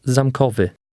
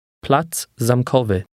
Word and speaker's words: Plac Zamkowy: Platz Zamkowy. Wy. Wy. Ko. Kowy. Zam. Zamkowy. Plac zamkowy. – Notice how the two words run together.